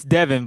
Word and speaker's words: Devon: Sorry, is it Devon or is it Devon? – It's Devon, but it's spelled Devon, you know? – Okay, D-E-V-O-N.